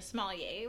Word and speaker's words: sommelier: Anyway, so you decided you wanted to go back to school for sommelier, which sounds amazing. Am I even saying that right? – Yeah.